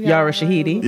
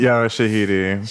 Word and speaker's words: Yara Shahidi: How you say it? Yara Shahidi? Sharari? – Yara Shahidi. – Yara Shahidi.